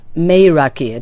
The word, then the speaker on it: meraki: This is a Greek word. Meraki, it's pronounced.